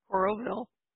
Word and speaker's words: Coralville: So it's Coral City or Coralville, Iowa? What is it? – Coralville. – Coralville, Iowa.